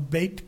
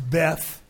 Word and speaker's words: Beit: They call them Beit Knesset. The Beit Knesset. Now, Beit could be pronounced Beth.